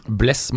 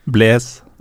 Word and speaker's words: Blaise: og så har vi jo en, da en toer da, i vår 4-2-3-1. hvem skal vi ta som en av våre to foran forsvarsrekka? Bless Matuidi er den ene. Bless? Kødder du, Det er sånn man uttaler det. Nei! Jo. – Blaise. – Blice.